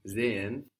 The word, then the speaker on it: sehen: The S on sehen sounds like a Z. And that's what happens when we have an S and a vowel starting a syllable.